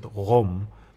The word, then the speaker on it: Qom: They actually pronounce it Qom.